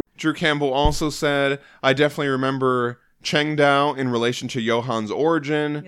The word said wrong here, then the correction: Chengdu: Yes, Chengdu.